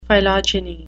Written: phylogeny

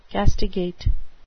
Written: castigate

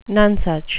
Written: nonesuch

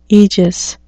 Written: aegis

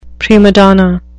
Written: prima donna

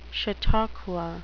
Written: chautauqua

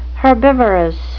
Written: herbivorous

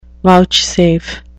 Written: vouchsafe

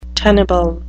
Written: tenable

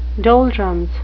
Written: doldrums

